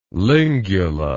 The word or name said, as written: lingula